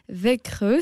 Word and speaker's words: Växjö: Växjö, c'est son nom, je pense.